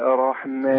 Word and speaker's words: الرحمن: الرحمن. – لا ما قلتش انا بنتقل من الهمزه للراء على طول. الرحمن. – الرحمن. – لا حضرتك ما تبصش للام اللي موجوده دي خالص، اللام دي شمسيه لا تنطق.